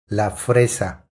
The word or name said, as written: La fresa